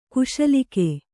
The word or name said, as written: ಕ್ಷುಲ್ಲಕ